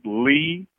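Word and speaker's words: Leigh: If you're on IG, it's just my name, Robert Lee Pruitt, and I'll spell it.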